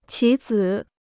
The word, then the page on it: 棋子: qí zǐ